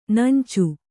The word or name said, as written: ನೆಂಚು